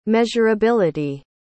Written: measurability

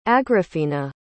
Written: agrafina

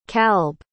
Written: kalb

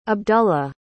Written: abdullah

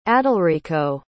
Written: adalrico

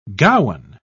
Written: gowan

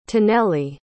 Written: taneli

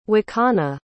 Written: wakana

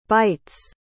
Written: bights

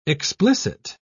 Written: explicit

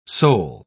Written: sol

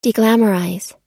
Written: deglamorize

